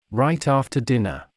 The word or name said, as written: right after dinner